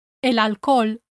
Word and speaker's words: alcohol: When you say el alcohol and el hospital, make sure your H is silent.